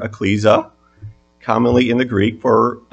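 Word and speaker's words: ekklesia: It is Strong's number 1577, and it is pronounced ecclesia in the Greek.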